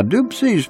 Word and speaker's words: Dopsie: Now, your host, Paul Edward Leslie, spoke with the legendary Rockin Dopsie Jr. Now, Dopsie's pronounced Dopsie.